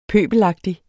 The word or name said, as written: pøbelagtig